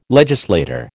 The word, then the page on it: legislator: American English